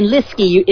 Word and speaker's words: Leske: And to get it right, it's Ryan Leske. It rhymes with whiskey.